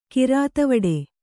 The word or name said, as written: ಕಿರಾತವಡೆ